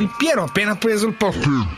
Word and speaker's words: Del Piero: Ah, per i futuri del Piero, Ho detto bene. Hai detto bene.